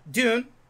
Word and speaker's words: Dune: It's either Dune... – June. – Yeah, some. It's nobody knows how to pronounce it. – I just say dune. – Yeah, like sand, sand dune. – Yeah. – No, it's like a sand dune.